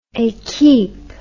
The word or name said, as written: equipe